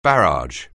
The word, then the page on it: barrage: anglais